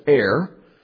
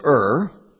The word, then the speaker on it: Er: I'm tempted to pronounce it Er, because to Er is human. But his name was Ur.